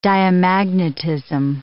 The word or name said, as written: diamagnetism